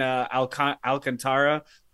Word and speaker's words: Alcantara: They've got the, you know, reigning Cy Young in Alcantara. I might be butchering that. – No, name. – You didn't.